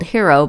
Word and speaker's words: Hiro: I'm referring to them as the Incredibles family sweaters because the design I'm spinning towards is a design called Hero by Julia Farwell Clay. And while it's spelled H-I-R-O,